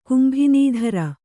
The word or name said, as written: ಕುಂಭಿನಿಧರ